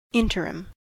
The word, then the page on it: interim: American English